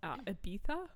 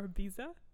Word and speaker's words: Ibiza: One is called Ibiza or Biza, depending on your preference of pronunciation.